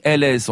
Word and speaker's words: Breuils: Mon cher Philippe, c'est vous qui avez demandé le nom Breuil, c'est Breuils. On l'écrit bien comme ça, on est d'accord, hein? – C'est ça, mais on prononce Breuil. – On prononce Breuil, on prononce pas le S, parfait.